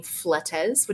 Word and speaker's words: Fletes: I'm on LinkedIn as Heather, and then my last name, Fletes, which is spelled like Fleets, F-L-E-T-E-S. And then I'm on Instagram,